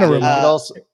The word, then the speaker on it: Anarumo: Lou Anarumo, am I saying that correctly? – Yeah. Anarumo.